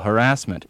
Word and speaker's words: harassment: He does not like the pronunciation of the word harassment with stress on the second syllable, harassment.